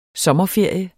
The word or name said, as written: sommerferie